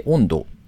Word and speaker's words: OND: 10 月 1 日からですね、東京の中目黒に新規オープンするコース料理レストラン、温度、 OND と書いて温度と読むんですが、そのお店でですね、後ネギめというサービスの提供を開始されるそうです。